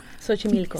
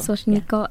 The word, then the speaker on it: Xochimilco: I feel like, please correct me if I say it wrong, Xochimilco? – Xochimilco. – Xochimilco.